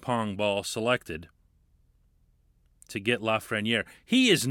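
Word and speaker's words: Lafrenière: The last name is Lafreniere,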